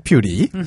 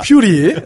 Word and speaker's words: 퓨리: 저 아까 릭 퓨어리 퓨어리 하시는데 저는 릭 퓨리. – 자 맞는 발음이죠. 하이드라 아니에요 히드라. 릭 퓨리.